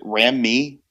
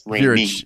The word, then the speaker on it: Ramey: I'm really glad that you're pronouncing my last name correctly, because normally people are like, oh, is it Ramey? No, it's, yes, but yes. I would, but no, it's Ramey.